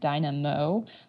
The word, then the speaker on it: Dynamo: There's actually a really cool web audio API toy called Tuna that's produced by the guys at Dynamo. That's spelled like two names, Dyna and Mo.